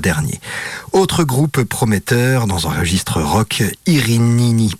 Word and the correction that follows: Irnini Mons: pardon, j'ai mal prononcé, Irnini Mons, jouait le jeudi dans le hall 3 du Parc Expo.